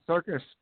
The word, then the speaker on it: Serkis: Serkis. Is that how you say his name? – Yeah, Serkis. Yeah.